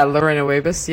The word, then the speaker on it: Lorena Wiebes: It's, you tell us how to pronounce it. Lorena Wiebes? – Yeah, Lorena Wiebes. Yeah, you've got it bang on.